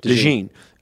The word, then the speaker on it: DeJean: Cooper DeJean? – DeJean.